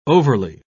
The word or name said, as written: overly